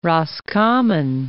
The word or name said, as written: Roscommon